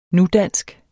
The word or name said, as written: nudansk